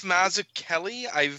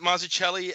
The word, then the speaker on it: Mazzucchelli: It's Mazzucchelli. I always pronounce it Mazzucchelli, and then someone around the time that Asterius Polyp came out when I was working at the comic shop corrected me to Mazzucchelli.